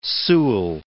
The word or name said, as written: Sewell